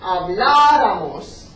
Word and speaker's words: habláramos: Acento fijo, decimos. Habláramos. Habláramos. ね、アクセントここやるので、ハトでここ強く言います。アブラモスじゃなくて、アブラモス。